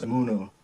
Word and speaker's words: Imuno: Oh, it's actually pronounced Imuno.